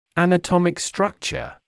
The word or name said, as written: anatomic structure